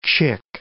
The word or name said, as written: chick